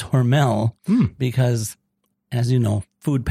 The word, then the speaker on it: Hormel: You might call it Hormel Food Company, but the correct pronunciation is Hormel.